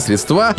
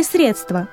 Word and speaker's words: средства: Запомните, ребята, в формах множественного числа существительных «благо», «блюдо», «средство» ударение не ставится на последний слог. Так произношение «блага», «блюда», «средства» является грубейшей ошибкой и свидетельствует о низкой культуре говорящего. – Правильно же следует произносить эти слова с ударением на первый слог. «Все блага», «ни за какие блага мира», «красивое блюдо», «вторые блюда», «выделенные средства».